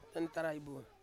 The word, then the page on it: nentarai bu: Ansus